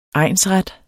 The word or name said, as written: egnsret